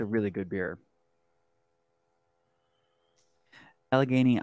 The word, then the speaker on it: Allegheny: Allegheny, I mean, Lakewood isn't that far from Allegheny State Park.